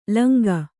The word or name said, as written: ಲುಂಗ